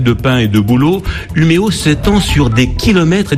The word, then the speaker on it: Umeå: D'abord parce que Umeå s'écrit Umeå, avec un drôle de petit accent sur le A qui se prononce O en suédois.